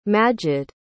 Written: majed

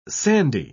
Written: sandy

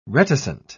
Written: reticent

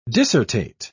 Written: dissertate

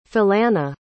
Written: Philana

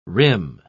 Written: rim

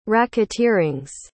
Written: racketeerings

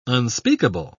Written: unspeakable

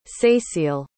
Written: cécile

